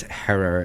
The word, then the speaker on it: Herer: And, you know, as he always said, it's Herer and it rhymes with terror.